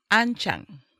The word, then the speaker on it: Anching: Anching. – Anching? – Yeah, just the way it's spelled. – Okay. – Nothing fancy.